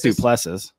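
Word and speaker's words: Du Plessis: I think it's Duplessis. – Duplessis? Okay. Yeah.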